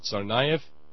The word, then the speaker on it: Tsarnaev: Well, I mean, they've got ethnically Russian names to the point that the first syllable of the word is czar. – Mm-hmm. – What is it, Tsarnaev? – Yes.